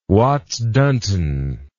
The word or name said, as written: Watts-Dunton